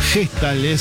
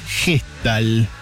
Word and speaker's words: Hestald: Gestalt, se escribe con H, así como suena, H-E-S-T-A-L-D, Gestalt.